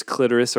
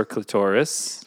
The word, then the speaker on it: clitoris: There's clitoris or clitoris. – Well, apparently both are correct pronunciations.